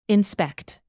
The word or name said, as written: inspect